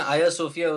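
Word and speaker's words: Ayasofya: वैसे मैं आया सोफिया उसको original नाम था, और हागिया सोफिया या हाजिया सोफिया हम कहते हैं. – हाँ ठीक है, तो I think आया सोफिया is the easiest for me to say.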